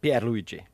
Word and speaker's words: Pierluigi: No, alltså genast jag tänker på ordet domare så tänker jag ju på, nu minns jag inte hur man uttalar bara förnamnet, men den här Colina. – Pierluigi. – Pierluigi Colina, si, molto bei.